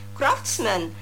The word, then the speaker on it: craftsman: كرافتسمان C-R-A-F-T-S-M-A-N، هقولها مرة تانية C-R-A-F-T-S-M-A-N كرافتسمان. بنلاحظ هنا آخر الكلمة فيها كلمة man، لكن أنا مش بنطقها man، بقولها من. كرافتسمان. كونفكشنر هو الحلواني، كونفكشنر C-O-N-F-E-C-T-I-O-N-E-R كونفكشنر. Artisan هو الحرفي الماهر، Artisan A-R-T-I-S-A-N Artisan، الاس هنا بننطقها ز ساوند.